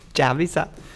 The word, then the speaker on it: Dzaviza: Dzaviza.